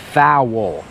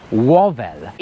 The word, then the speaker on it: vowel: I też od razu powiem, że samogłoska się wymawia vowel. To jest v, a, w, o, vowel.